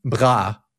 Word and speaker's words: bras: Bras, B-R-A-S, but pronounced basically bra in French, is your arms, not your bra.